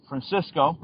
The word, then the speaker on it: Francisco: It's Francisco, that's my full name, Francisco Robles, R-O-B-L-E-S.